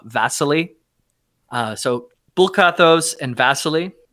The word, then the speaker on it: Vasily: I would almost say it might be easier to say, but I think you're saying it wrong. I think it's Vasily. – Yeah. – Vasily. – Okay, sure. That's the Eastern or Russian name that's in real life. Vasily. – V-A-S-I-L-Y? – Vasily, yep.